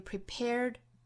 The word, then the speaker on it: prepared: prepare 这个词后面加字母 d，读成浊辅音的 d。 I am prepared 虽然听起来不太清楚了，但是我们要知道它其实本质上是一个 d 的发音，只不过在单词尾部要收音，不像我们汉语之中发 student China 哈这样的音，我们要把它弱回来，要把音收回来，读得非常轻非常短，不去拖音不代表它是轻辅音。